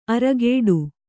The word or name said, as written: ಅರಘಟ್ಟ